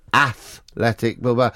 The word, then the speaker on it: Athletic: Well, you're saying it wrong. Because it's Atletico Madrid, Atletico Zaragoza, but Athletic Bilbao, because it was started by English people.